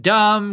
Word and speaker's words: dumb: And then there's a whole adorable thing about spelling and Jamie misspelling dumb because there's a silent B and he's 4.